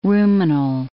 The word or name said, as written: ruminal